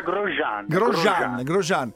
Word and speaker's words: Grosjean: Comunque si pronuncia Grosjean.